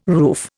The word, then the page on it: roof: Indian